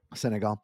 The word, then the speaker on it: Senegal: I don't know if I'm pronouncing that right or not. – Yeah, but Senegal. Yeah, you're right. – Senegal.